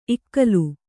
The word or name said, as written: ಇಗ್ಗಾಲಿ